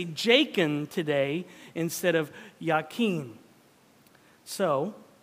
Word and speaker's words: Jachin: Now, the Hebrew pronunciation of Jachin is actually Yakin, but we're just going to pronounce the J of the English translation, okay?